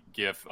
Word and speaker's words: gif: Insert the gif of Tommy Lee Jones. Yeah, that's right. I say it with a hard G.